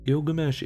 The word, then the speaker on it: Gilgamesh: Well, in Sumerian, his name possibly would have been pronounced Bilgamesh. Gilgamesh is actually the Akkadian pronunciation of the name.